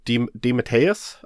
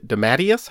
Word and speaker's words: DeMatteis: DeMatteis, I think. J.M. DeMatteis. – That's a fun way to, to say that. – D- Dematius? How do you say that? – I'd say Dematis.